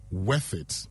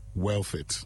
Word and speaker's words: worth it: Could you consider discussing the word worth it in your next Say It Right? A lot of people mispronounce it as wealth it.